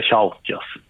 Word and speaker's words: Chauchas: Nu redde vi ut uttalet på byn. – Ja. Nej, det är Chauchas som gäller.